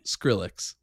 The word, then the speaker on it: Skrillex: Skrillex. Am I saying that right? – Skrillex. – Oh, my gosh. I've been saying it wrong the whole time. – No, I think you've said it right.